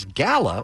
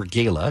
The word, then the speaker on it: Gala: apple, as Gala, or Gala, depending on how you pronounce it, takes the number 1 spot.